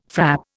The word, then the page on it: frap: Indian